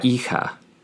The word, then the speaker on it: hija: El hijo y la hija el hijo y la hija. Keep in mind that H at the beginning of both words is silent.